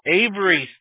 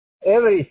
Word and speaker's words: Avery: Oh, Avery Street. – Avery Street, sí.